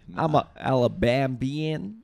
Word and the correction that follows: Alabamian: Alabambian? – Alabambian. Like, what the fuck are they called? – Right. Alabamians.